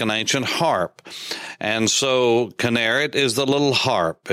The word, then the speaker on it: Kinneret: And number four, the primary name, Kinneret, K-I-N-N-E-R-E-T. Some would spell it K-I-N-E-R-E-T, but it's Kinneret.